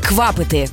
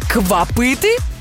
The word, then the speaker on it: квапити: Наголос, друзі, на першому складі – квапити. Бо якщо сказати «квапити», то це вже звучатиме ніби нова назва енергетика.